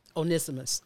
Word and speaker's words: Onesimus: Onesimus.